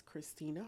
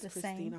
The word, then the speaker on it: Khrystina: I am on all platforms as Christina Harvey, and my name is spelled different. So it's KHRYSTINA. And Harvey is spelled normal, HARVEY. Right. But I'm on all platforms as Christina Harvey.